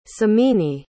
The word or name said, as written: simini